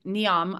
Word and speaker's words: Neom: And as I understand, like Neom, I don't know if I said that correctly.